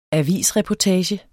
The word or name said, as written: avisreportage